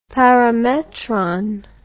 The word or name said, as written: parametron